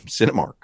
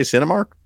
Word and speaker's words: Cinemark: Somebody says, why aren't you pronouncing the K in Cinemark? I thought I did every time. Cinemark. Didn't I say Cinemark? – I heard Cinemark.